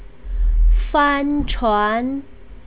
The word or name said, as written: fanchuan